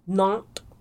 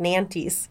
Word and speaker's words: Nantes: This story takes place in Nantes, France. I looked up how to say it. It's spelled N-A-N-T-E-S. I would have been like Nantes. Nantes, France. – Nantes.